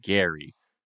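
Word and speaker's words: Gerry: whose name is spelled like Jerry but pronounced Gary to experience the whole bachelor process with.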